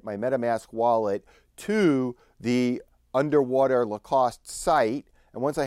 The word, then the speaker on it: UNDW3: It's called the UNDW3 card, and I believe that UNDW3 is pronounced underwater, the underwater card.